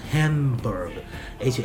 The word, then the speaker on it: Hamburg: 这个 berg 呢，在德语里边它是山的意思啊，所以现在我们著名的几个德国的地名，像什么 Hamburg，H A M B U R G，B U R G 实际上和 B R G 是一样的啊，发音是一样的。